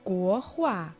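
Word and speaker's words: 国画: Гохуа. Го – это страна, государство. Произносится во втором тоне. А хуа означает картина, живописи в третьем тоне.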